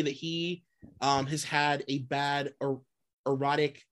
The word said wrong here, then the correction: aortic: Aortic valve.